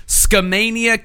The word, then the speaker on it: Skamania: Yeah, and he lived in, let's see here, Skamania County in Stevenson, Washington. – Okay. – It's actually pronounced Ska-mania, and they listen to a lot of Less Than Jake.